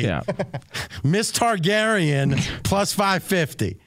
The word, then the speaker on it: Targaryen: Is that how you say it? – It's a new dinosaur, actually. It's Tyrion Lannister. – Yes. Yeah. No, it's not Lannister. – Oh, Daenerys Targaryen. – Yes, it's Targaryen.